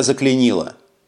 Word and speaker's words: заклинило: «Мотор у меня заклинило!» Информирую я, делаю ударение на второй «и».